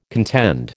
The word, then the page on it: contend: American English